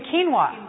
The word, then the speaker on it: quinoa: Are canola and millet, you haven't mentioned them, are they? – Oh, you mean quinoa.